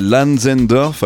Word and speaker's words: LNZNDRF: Oui, c'est Lanzendorf. Alors, euh, je le dis comme ça, mais ça s'écrit avec uniquement les consonnes L-N-Z-N-D-R-F.